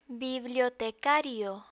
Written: bibliotecario

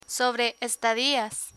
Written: sobre estadias

